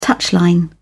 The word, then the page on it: touchline: British English